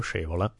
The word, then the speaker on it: Scaevola: And again, by the way I pronounce it is sort of Italian pronunciation. The Latin, there are three gazillion ways to do it. Mutius Scaevola, Mutius Scaevola, Mutius Scaevola. Nobody freaking knows, you know. There's a lot of guessworks about this. In any case, I'll give you the Italian version.